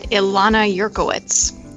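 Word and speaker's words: Ilana Yurkiewicz: It's at Carolyn Lai, spelled L-Y-E. – And Ilana, what's yours? – At Ilana Yerkowitz. – Do you want to spell that for folks? – Yeah, good idea. It's I-L-A-N-A-Y-U-R-K-I-E-W-I-C-Z.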